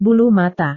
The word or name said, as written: bulu mata